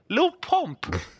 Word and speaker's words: Lil Pump: Lil Pump. Why Lil Pump? – Lil Pimp, like, man. What, Lil Pimp? – He didn't even say his name correctly. You know, but called him Lil Pimp.